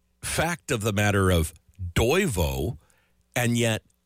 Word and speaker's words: Toivo: And the first one, Toivo, that's how you say it. Toivo, spelt T-O-I-V-O.